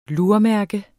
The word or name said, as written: lurmærke